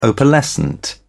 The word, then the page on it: opalescent: British English